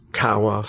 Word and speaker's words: Kowa: It's pronounced Kawa. Some people pronounce it different ways. I don't know. It's spelled K-O-W-A.